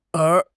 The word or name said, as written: er2